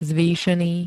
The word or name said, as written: zvýšený